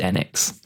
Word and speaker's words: Enix: I think it's an American thing. I find that Americans tend to change the pronunciation of certain words, and Enix stuck here, but it is Enix. That's how the Japanese say.